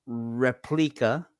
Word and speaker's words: Replika: Okay, the first one was called Replika, and that's spelled R-E-P-L-I-K-A.